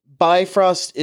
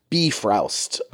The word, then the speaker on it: Bifrost: Okay, for listeners, listen, Bifrost is a perfectly accepted way of pronouncing it. But in Old Norse, it is something along the lines of Bifrost.